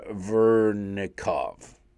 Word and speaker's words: Vernikov: This is a letter sent out by Ina Vernikov, V-E-R-N-I-K-O-V. I think Vernikov, I think I actually pronounced that.